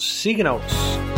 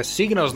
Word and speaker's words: Signals: Nosso oitavo álbum de 1982 é o Signals. É, isso mesmo, meu amigo. É Signals, não é Signals, não.